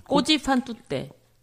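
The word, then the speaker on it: Così fan tutte: 꼬지 판뚜떼.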